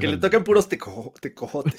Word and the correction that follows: tejocotes: Tejocotes. – ¿Tejocotes?